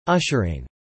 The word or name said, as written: ushering